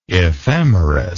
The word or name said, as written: ephemerous